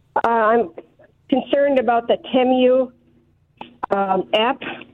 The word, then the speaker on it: Temu: It's pronounced Temu, by the way. – Okay. – That's all right. It's called Temu, and it does.